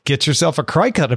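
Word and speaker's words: Cricut: The entire Discord is all over this because I've now been informed that it's pronounced like cricket. – Oh, that makes sense. Yeah. – Yep. – Cri-Cut.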